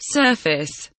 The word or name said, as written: surface